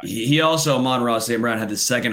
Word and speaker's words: Amon-Ra: Amon-Ra St. Brown. It's the sun god.